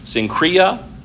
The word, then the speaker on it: Cenchreae: it was at Cenchreae,